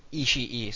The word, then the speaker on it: Ishii: And the only reason I think that last name is pronounced like that is this is going to be also nerdy, but on Kill Bill, whenever she kills Oren Ishii, the name is spelt very similar, and it's pronounced Ishii, so that's the way I'm sticking to it.